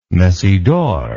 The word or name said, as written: messidor